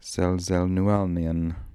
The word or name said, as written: selzelnualnian